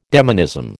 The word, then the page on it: demonism: American English